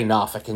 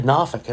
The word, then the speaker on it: Norfolk: Well, let's just say Norfolk in general. Side note, I say Norfolk because that's how it's pronounced.